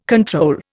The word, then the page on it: control: Indian